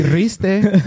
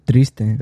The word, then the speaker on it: Triste: Yeah, How did you say it earlier? He's like, Riste. – It's called Triste.